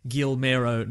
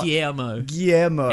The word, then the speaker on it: Guillermo: Now, before we get to spoilers, somebody just tweeted, listening to Old Weekly Planet Pod, and noticed that Mr. Sunday Movies always pronounces Del Toro's name Gilmero, not Guillermo.